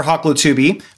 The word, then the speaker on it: Hoklotubbe: So my name is Thomas Christopher Hoklotubbe, but that's how I pronounce it. I recently made a visit to Oklahoma for my research, and I introduced myself to friends there, and, you know, they looked at me like, not look, they heard my name like, what is that? I'm like, oh, no, I'm sorry, Hoklotubbe. And I've tried to resist this pronunciation because it invites Teletubby.